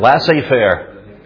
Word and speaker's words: laissez-faire: Laissez-faire.